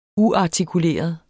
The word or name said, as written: uartikuleret